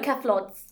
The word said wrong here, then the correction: cephalopods: Cephalopods. It is cephalopods.